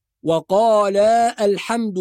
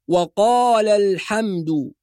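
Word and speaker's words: وقالا الحمد: بعض الناس يقول وقال الحمد لله، وهذا خطأ، لأن ألف وقال ساكنة، وهمزة الوصل في كلمة الحمد ساكنة، والقواعد اللغوية تقول بحذف هذه السواكن، يعني ننطق اللام في كلمة وقال بعدها لام كلمة الحمد، فنقول وقال الحمد.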